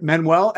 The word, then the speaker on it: Manuel: So D-A-I. – That's right. Manuel, M-A-N-U-E-L.